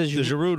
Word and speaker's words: Giroud: The Giroud rule.